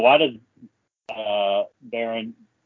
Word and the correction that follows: Baran: am I saying his name right? Is it Baron? What is his, I can't remember. – It's something like that. – Baran. – Oh, Baran. Yeah, Baran.